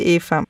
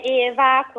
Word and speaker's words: Eva: No, è Eva, come si Eva Braun, perché appunto il padre è un simpatico studioso di Holocaust and Film alla Humboldt Universität di Berlino. Sì, quindi lo pronuncerei Eva.